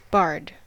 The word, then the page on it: bard: American English